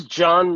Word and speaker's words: Jawn: My name is John Murray. John is spelled J-A-W-N because I have a black mom, and sometimes they like to be unique with the spellings of our name.